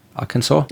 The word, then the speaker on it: Arkansas: In einer Zeitschrift, im Journal of the Arkansas, wie spricht man Arkansas aus? Arkansas? – Ja, so.